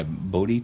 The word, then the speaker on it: Bodhi: B-O-D-H-I, Bodhi tree. It's in India. – Bodhi. Bodhi as in becoming a bodhisattva.